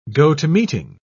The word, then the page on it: go to meeting: British English